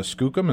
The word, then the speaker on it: Skookum: She has, this being her first book for adults, she's written a book called Valley of the Skookum, and I hope I'm pronouncing that correctly, Sally. It's Valley of the Skookum, is that right? – Yes.